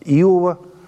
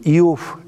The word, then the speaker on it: Иов: В наш раздел учительной литературы входит 5 книг от Иова до «Песни песней». Что касается названия книги, мы говорим «Иов» – это славянское произношение, «Иов» – это русское произношение. Поэтому и так правильно.